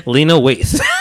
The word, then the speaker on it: Lena Waithe: Lathane White. Did I say her name right? – No, you did not. – Okay, how do you say her name? – Lena Waithe.